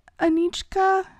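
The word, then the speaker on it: Anicka: I feel like it could be Anichka. So it's A-N-I-C-K-A, and the C-K is what gives it... – The k-chk.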